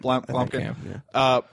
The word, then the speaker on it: Blomkamp: Did I pronounce that right? – Blomkamp. – Blomkamp. Perfect director for this.